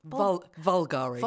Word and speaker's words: Bulgari: That started with them in Bulgari. Bulgari, is that how you say it? – Vulgari. – Vulgari. Sorry, Vulgari. Mm.